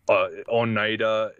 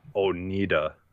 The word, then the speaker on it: Oneida: Like, I always love the GPS, Google GPS always says, pronounces, it's like Oneida, it pronounces it Oneida. – Oneida, yeah. – It's like, no, it's Oneida.